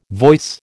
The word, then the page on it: voice: American English